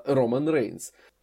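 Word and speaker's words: Roman Reigns: Все-таки один из них это главное приобретение Смакдауна во время Суперстар Шейкап, а второй это Роман Рейнс.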